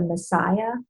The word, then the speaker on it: Masaya: For children, I particularly like the Messiah products, which is not spelled Messiah. It's spelled M-A-S-A-Y-A, Messiah Oils.